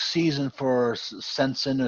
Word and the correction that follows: Senyshyn: or Sensina, however you want to pronounce it. – Senyshyn. – Senyshyn. I always get it wrong.